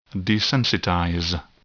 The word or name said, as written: desensitize